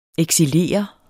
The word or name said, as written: eksilere